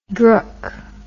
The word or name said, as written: drook